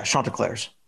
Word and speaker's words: Chanticleers: Oh yeah, no, it's Chant. Yeah, Chanticleers. Yep.